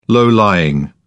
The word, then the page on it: low-lying: British English